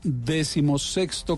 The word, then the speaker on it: decimosexto: Entonces, ese XVI que, es, que va en mayúsculas se, debe decir decimosexto Congreso Nacional de Infraestructura, que va a tener lugar próximamente en Cartagena.